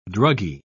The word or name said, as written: druggie